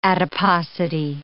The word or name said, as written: adiposity